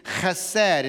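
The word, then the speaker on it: chesed (חסד): And the word love in the Hebrew is chesed. And it's kind of a guttural sound, remember? You've got to say it that way. You've got to spit just a little bit to say it right.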